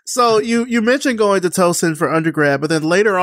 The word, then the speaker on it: Towson: So later on, you know, you mentioned going to Tucson. Am I saying that right? – Towson.